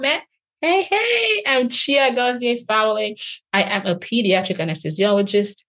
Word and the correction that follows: Chiagozi Fawole: And I just chuckled because anybody hearing how I just pronounced my name will have to basically laugh. They're like, no, it's Chiagozi Fawole.